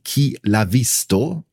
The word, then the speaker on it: Chi l'ha visto: Andreas Herzog tritt sogar im italienischen Pendant zu Aktenzeichen XY Chi l'ha visto auf Deutsch Wer hat es gesehen auf.